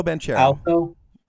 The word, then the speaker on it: Banchero: Paolo Banchero.